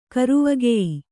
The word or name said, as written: ಕರುವಗೆಯ್